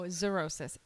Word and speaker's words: xerosis: It's it's xerosis. – It's xerosis? – No, xerosis. X-E-R-O-S-I-S.